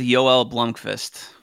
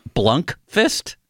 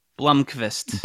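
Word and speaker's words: Joel Blomqvist: I was going with Yoel Blomqvist. – Blunkfist? – Blomqvist. – Blomqvist. Okay. You want to try that one on for size limits? – Yoel Blomqvist? – No, Yoel Blomqvist.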